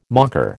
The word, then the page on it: mocker: American English